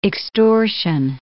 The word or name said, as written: extortion